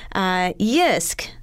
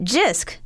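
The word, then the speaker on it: Jysk: He says, Yisk. Often pronounce it Jisk because it's a J, but it's not. The J-I is a Y sound.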